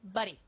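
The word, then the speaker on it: Budde: I mean, seriously, the fact that they lost their mind, this is what we need more of is, am I saying her name right? Bishop Buddy. – Buddy. Yes.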